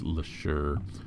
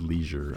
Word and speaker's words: Lesure: Actor James LeSure. Or James Leisure. I think it's LeSure, but it's spelled like Leisure.